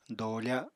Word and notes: doofa: apatani

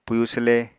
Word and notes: pwyu swle: Apatani

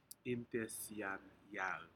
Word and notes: intesianyac: Aneityum

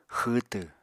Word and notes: xwwtw: Apatani

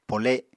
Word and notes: pole7: Apatani

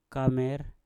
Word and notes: kamer: Mpur

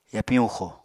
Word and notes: yapiñ huxo: Apatani

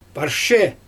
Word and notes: parshe: Xyzyl

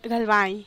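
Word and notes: galbai: Teotitlán del Valle Zapotec

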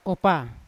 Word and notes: kopa: Yawa

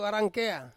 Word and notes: warangkea: Ansus

